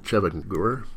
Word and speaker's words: Chevengur: You write about Plotinoff's novel, Chevengur, is that how you pronounce it? – Yeah.